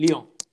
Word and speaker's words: Lyon: Okay, and then moving on to the final day, next Wednesday, we've got um, Leon Lyon, Lion. Is that yeah. how do you pronounce it? Lyon. Yeah, Lyon.